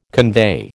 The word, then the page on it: convey: American English